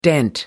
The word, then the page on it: dent: American English